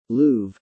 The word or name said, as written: Louvre